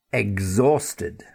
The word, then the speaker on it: exhausted: And then exhausted. And here in this word, E-X-H, but the H is silent. So don't forget that pronunciation as well as getting the X sound correctly.